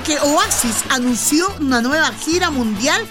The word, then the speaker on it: Oasis: En inglés, lo Oasis. Pero acá le decimos Oasis porque Oasis. Se escribe Oasis y se pronuncia Oasis.